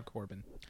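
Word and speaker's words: Corbijn: Yeah, Anton Corbin. – The J is silent.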